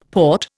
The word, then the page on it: port: Indian